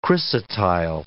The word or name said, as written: chrysotile